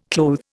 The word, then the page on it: clothe: Indian